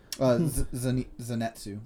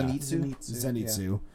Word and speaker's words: Zenitsu: Zenitsu. – Yeah. – Zenitsu. – Yeah. Zenitsu. But yeah, enough. – I was stressing the wrong syllable.